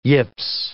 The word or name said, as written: yips